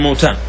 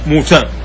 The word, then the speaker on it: موتان: قال: ثم موتان، ثم موتان، البعض ينطق ويقول موتان، والصحيح أنها موتان بضم الميم وسكون الواو، ومعنى هذا قال: ثم موتان، النبي صلى الله عليه وسلم يقول: يأخذ فيكم كقعاس الغنم أو كقعاس الغنم.